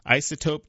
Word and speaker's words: Iceotope: Isotope Technologies, and that's spelled I-C-E-O-T-O-P-E, Technologies, which creates cooling solutions for data centers, plans to open its first American office in Research Triangle Park just off Davis Drive.